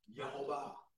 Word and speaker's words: Yehovah: In Hebrew it's spelled Y-H-V-H. It's Y-H-V-H. That's why it's pronounced Yehovah. – Mm-hmm. – It's not pronounced with a J. It's pronounced with a Y. – Yeah. – Yehovah.